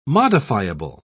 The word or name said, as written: modifiable